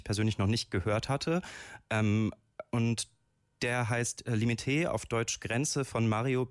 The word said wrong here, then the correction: Limite: Tatsächlich spricht man das Limite aus.